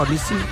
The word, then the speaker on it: Olise: Olisi. – ¿Qué? – Olisi, el de Francia. El de Francia no es Michael Olise, ¿cómo es que se llama? Olise.